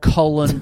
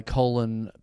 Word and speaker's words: Colin: You know, why is that with that guy that just died in America, the military guy, Colin Powell? – Mm-hmm. – He was always pronounced Colin, but his name's spelled, it's Colin. – I think that's just the American pronunciation.